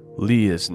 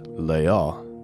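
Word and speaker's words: Leah (לאה): Leah's name, it's spelled Lamed Aleph He, and it is pronounced Leah. Leah, so very, very close to the English.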